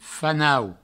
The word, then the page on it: fanau: Provençal